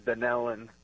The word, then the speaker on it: Dunellen: No, actually, it's pronounced Dunellen.